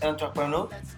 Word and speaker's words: entrepreneur: El término emprendedor del francés, entrepreneur, ¿lo dije bien? – Sí, perfecto.